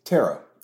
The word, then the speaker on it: tarot: Tarot. – Yeah. Is it tomato, tomato? – Oh, tarot. So the T is tarot. Silent. – The T is silent.